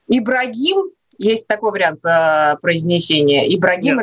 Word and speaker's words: Ибрагим: Но, кстати, пишется по-разному. И в всяких википедиях, и в словарях кто-то пишет Эбрагим, кто-то пишет Ибрагим. Но произносится вот так более мягко – Ибрагим. – Ибрагим.